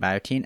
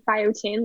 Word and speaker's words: Biotein: So, can you tell us what Biotein is, if I'm pronouncing that right, Biotein, and what was the motivation behind it? – Yeah, for sure. Thank you for pronouncing it right. Actually, a lot of people pronounce like biotin, like the hair molecule.